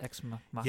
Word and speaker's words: Ex Machina: Is that how you pronounce it? Ex Machina? – Yes.